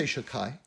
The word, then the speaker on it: Shaddai: God, at a certain point, said this divine name, Shaddai, which is, incidentally, we'll say Shakai, but incidentally, it's spelled Shin Dalad Yud, and that's the name of God that's on all of our mezuzahs, on the outside, on the case.